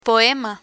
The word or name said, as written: poema